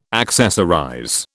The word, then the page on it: accessorize: British English